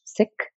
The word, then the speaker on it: Sikh: And if you know the Sikh tradition, S-I-K-H, Sikh, a lot of people pronounce it Sikh. Just want to say it's actually Sikh.